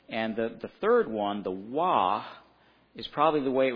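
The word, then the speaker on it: Waw: If you a Hebrew today, they would say Vav in the Hebrew today. The V is how they would pronounce it. But in the biblical times, it was a Wah.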